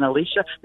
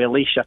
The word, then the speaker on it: Elisha: That's how I pronounce it in the movie, by the way, Elisha. – Elisha. – To make the distinction between Elijah and Elisha. – I see. – Because otherwise they sound too close together.